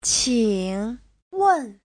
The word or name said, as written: qing3wen4